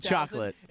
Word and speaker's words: Chocolat: So it is pronounced Chocolate. – Johnny Depp is in it. It is not pronounced Chocolate because there's no E at the end. It's Chocolat.